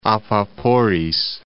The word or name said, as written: Apaporis